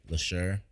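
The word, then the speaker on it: Leshurr: So Glorilla, JT, LeChat, Lady Leisure or LeSure, how you pronounce that? LeSure.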